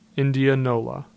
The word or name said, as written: Indianola